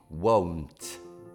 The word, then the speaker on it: won't: Allora, want è, pronuncia in italiano sarebbe U-O-N-T, want, mentre negativo a will, c'è un U in più, è U-O-U-W-O-U, U-O-U-N-T, won't. Quindi se ti dico non vorrò una birra, I won't want.